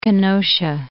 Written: Kenosha

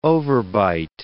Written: overbite

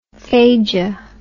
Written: phagia

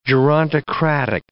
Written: gerontocratic

